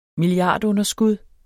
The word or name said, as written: milliardunderskud